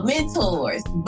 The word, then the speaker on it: Men on Tour: It is a movement, and it's actually pronounced oh my gosh, it's actually pronounced mentors, but it's spelled Men on Tour. So the whole concept of this is so great.